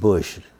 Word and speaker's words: Bueche: Spell Boucher, but they pronounce it Bush, Louisiana. – So that's Boucher. – Right. – B-U-E-C-H-E. So I thought that was Bush. That's the way everybody else said.